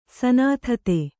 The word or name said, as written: ಸನಾಥತೆ